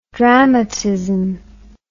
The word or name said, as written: dramatism